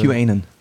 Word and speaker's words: QAnon: Kanon-Fans, oder Kanan, weißt du die für? – QAnon. – QAnon spricht man das? – Ja, ich glaube.